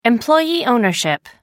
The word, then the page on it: employee ownership: American English